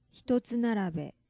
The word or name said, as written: hitotsu narabe